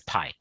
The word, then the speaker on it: Pai: First name you got right, Madhu. Last name is Pai.